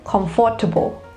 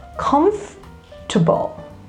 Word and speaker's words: comfortable: But it's actually no, because the second vowel, which is the O, the second O, is silent. You need to stress on the first syllable and hear how it goes. Comfortable.